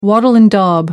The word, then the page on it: wattle and daub: American English